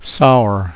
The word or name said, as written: sour